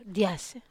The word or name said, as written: as